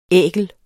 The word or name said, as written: ækel